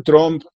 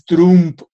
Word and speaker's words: Trump: Eh, dice, no se dice Trump, es Trump, con U, como dice el presidente. Y con N, Trump.